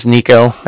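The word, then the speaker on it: Niko: Again, my name is Nico. That's N-I-K-O. – Yeah. – And then the Bank of Cardiff is spelled C-A-R-D-I-F-F, as in Frank Frank.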